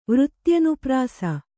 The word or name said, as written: ವೃತ್ತ್ಯನುಪ್ರಾಸ